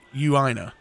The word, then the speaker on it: Eugenio: I missed this one yesterday, but this one is for, he spells his name Eugina. He says it's pronounced Euina.